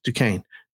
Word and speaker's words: Duquesne: It's spelled Duquesne. I've heard Duquesne in the books for years. It's Duquesne. Duquesne Dukes.